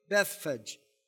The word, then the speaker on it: Bethphage: It's got a long E at the end of it, Bethphage.